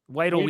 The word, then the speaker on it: Weidl: Um, but when they got, uh, I say his name wrong all the time. I, I uh, Andy Whittle. – Weidl, Weidl, something like that.